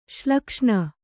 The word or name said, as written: ಶ್ಲಕ್ಷ್ಣ